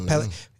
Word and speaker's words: Pellegrino: Pellegrino.